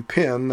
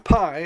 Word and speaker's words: pi: P, not pi, as the mathematicians often pronounce it. P as in pig.